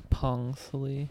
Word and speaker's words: Phongsali: You don't pronounce the H, so it's Pongsali.